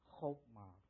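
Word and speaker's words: chokmah (חכמה): The first word for wisdom in Hebrew is the word, in Hebrew, it's pronounced chokmah.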